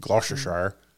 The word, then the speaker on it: Gloucestershire: I'll tell you, this is in Gloucestershire, England, which is probably pronounced Gloucestershire. – I think you probably nailed it.